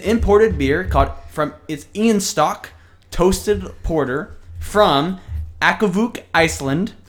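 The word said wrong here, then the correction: Akureyri: Okay, no, not even, that wasn't even close. – Akureli, Iceland.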